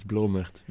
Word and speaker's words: Blomert: It's Blomert. But Blomert. It's difficult for English-speaking people, I think.